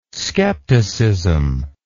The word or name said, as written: scepticism